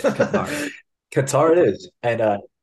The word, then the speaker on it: Qatar: Qatar. Good job. I'm so proud of you. Nailed it. Last week we talked about it and he pronounced it as cutter. Guys, cutter.